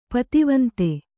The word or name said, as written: ಪತ್ತವಂದ